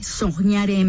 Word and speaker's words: soñare: Se pronuncia soñare, se escribe con qué, soñare MX.